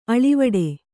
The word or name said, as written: ಆಳವಾಡು